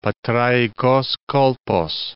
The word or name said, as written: Patraikos Kolpos